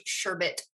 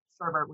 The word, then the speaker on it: sherbet: I'm an orange sherbet person. Pronounced it correctly. – You did. – we're in Oklahoma, so I mean, sherbet would be okay too.